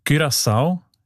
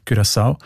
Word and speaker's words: Curaçao: Habe ich gelernt, spricht man eben mit «ü» aus, weil Holländisch dort auch eine wichtige Sprache ist. Und im Holländischen ist das «ü» offenbar häufig als «ü», Curaçao.